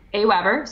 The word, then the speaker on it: AWeber: AWeber.